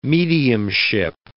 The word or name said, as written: mediumship